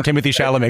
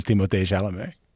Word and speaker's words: Timothée Chalamet: I love that I can tell you're Canadian because you get his name right in French, by the way. Everyone calls him Timothée Chalamet except for the Canadians who know his name is Timothée Chalamet.